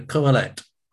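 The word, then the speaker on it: Qoheleth: The Kohelet, okay? Spelled with a Q, Q-O-H-E-L-E-T-H, the Kohelet.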